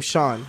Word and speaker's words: Sean: Or spelling the name Sean. Have you ever spelled the name Sean? – Yeah, there's but there's three. Multiple. There's, I was gonna say, and guess how one of those is spelled? S E A fucking N. There's no H, but you pronounce it with an H.